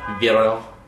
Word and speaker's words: Vero: What's that new social network? Vino? – Oh, Vero. – Yeah. – I believe it's pronounced Vero.